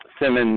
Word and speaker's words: Sim: Yeah, Simon, Michigan.